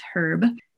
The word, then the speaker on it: herb: I always say it properly for the Australians, herb.